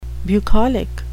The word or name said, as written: bucolic